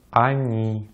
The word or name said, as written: они